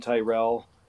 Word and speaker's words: Tyrrell: It's just @bobtyrell, all one word, 2 R's and 2 L's in Tyrell.